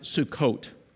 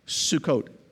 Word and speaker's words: Sukkot: It's called, we pronounce it Sukkot. Sukkot. Our Jewish friends practice it to this very day, the Feast of Sukkot.